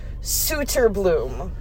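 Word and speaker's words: Söderblom: We had I'm gonna pronounce the way that Laz said in the tweet that it should be pronounced. – Okay. – Arvid Suterbloom.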